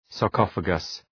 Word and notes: sarcophagus: sɑ:r’kɒfəgəs

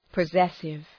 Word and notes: possessive: pə’zesıv

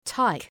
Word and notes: tyke: taık

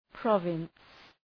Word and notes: province: ‘prɒvıns

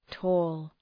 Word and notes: tall: tɔ:l

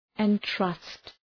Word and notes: entrust: ın’trʌst

